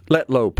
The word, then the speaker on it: Letlow: So he lost his – Letlow, pardon me, I mispronounced it.